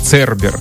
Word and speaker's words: Цербер: Мне все-таки кажется, что она читается как Цербер.